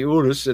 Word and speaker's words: Urus: So this sits on the same platform as the Porsche Cayenne, the Bentley Bentayga, and the Lamborghini Urus, U-R-U-S, however you want to pronounce that. – Yeah, be careful. – Our Italian and Spanish-speaking friends perhaps could chime in on that. Urus is how I say it.